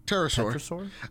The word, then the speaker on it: pterosaur: The wings of the Petersaur? – Yeah, Pterosaur. I think the P is silent.